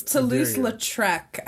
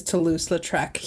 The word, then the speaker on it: Toulouse-Lautrec: It's Toulouse-Lautrec, I think, is like. – Okay. – I think that's how you pronounce it. If you're a horrible American, is Toulouse-Lautrec.